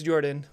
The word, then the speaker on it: Jordan: My name is Jordan, with a silent PH in the middle, and I am joined always by my wonderful co-host, Sir Lucian, over at Sir Lucian Gaming.